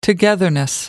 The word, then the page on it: togetherness: American English